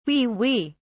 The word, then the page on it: wee wee: British English